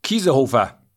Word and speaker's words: Kiesenhofer: And how do I pronounce her last name? Kaisenhofer? Kiesenhofer. Kies- Kiesenhofer.